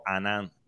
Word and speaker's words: Hanan: Se llama así, y lo buscan bajo Anan, que se escribe H-A-N-A-N.